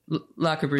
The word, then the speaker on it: Le Corbusier: Le Corbusier, the notion, is that how you say his name? Corbusier. – Yeah. – I don't know if I'm saying that right. – Le Corbusier.